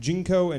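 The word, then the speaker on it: Ginkgo: Anyway, the chocolate in them, the Jinko. Yeah, that's how it's pronounced. Jinko and chocolate counteract the negative side effects of caffeine.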